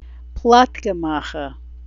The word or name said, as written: plotkam